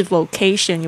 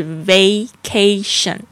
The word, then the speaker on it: vacation: 这个幻想一下这样的情节，如果现在呢你可以休三个礼拜的假期 vacation，特别注意一下它的发音，不是 vocation 也不是 vacation，是 vacation， v-a-c-a-t-i-o-n vacation 假期。